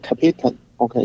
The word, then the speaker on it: Capitan: 就是在这个，在我们说的酋长岩 El 嗯 El Capitan，或者实际上应该念作 El 呃呃 Capitan，OK，因为它是一个西班牙语。